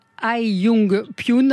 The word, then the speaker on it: Hyeyoung Pyun: Hai Yung Pyun, alors ça s'écrit Hyeyoung Pyun.